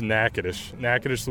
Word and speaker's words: Natchitoches: Yeah, it's Natchitoches, Louisiana. You get so many different ones. People are like Natchitoches or Natchitoches, or I mean, there's some terrible ones out there, but yeah, Natchitoches, Louisiana.